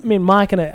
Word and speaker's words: Machina: Machina, I believe, is the Latin.